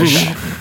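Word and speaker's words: Michell: That's not how you say it. It is how you say it. Roger Michelle. You said it right.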